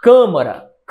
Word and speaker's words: câmara: Qual a sílaba mais forte? Câmara. É a primeira, sim. Nesta ordem, é a antepenúltima.